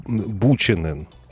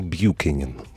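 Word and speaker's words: Buchanan: Его, как правило, говорят, называют Рой Бьюкенен. Вообще он Бьюкенен. Каждый раз, когда... – Но сложно, да. – Он... Там CH, но я H понимаю, не да. читается. Бьюкенен, и все.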